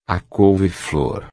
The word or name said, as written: couve-flor